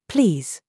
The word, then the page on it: ZOOS: British English